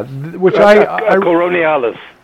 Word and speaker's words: Coriolanus: I want to pronounce this right. Coriolanus? Let's see. – Yes. – Yeah. – Coriolanus.